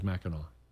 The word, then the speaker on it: Mackinac: And so they pronounced it the way it's spelled, and the French don't do that. So we get Mackinac turns into Mackinac. – So no matter how you see it, it's always Mackinac.